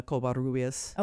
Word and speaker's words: Covarrubias: Or if you want to pronounce it like most people, Covarrubias. Cova what? Cova, Cova. Yeah. Like, yeah, nobody can pronounce my name.